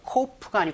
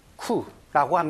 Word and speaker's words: coup: Coup, 우리말로는 이제 쿠데타라고 하고요. 영어로는 coup가 아니고 coup라고 합니다. P가 발음되지 않는다는 것도 알아두시고요.